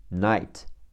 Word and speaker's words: night: n i g h t night，字母 i g h 组合读 i，n 读 n，n i night 加上 t night night，形容词奇怪的、滑稽好笑的，funny f u n n y funny f u 读啊发，n y 读 n i n funny funny，既是动词也是名词，锻炼练习。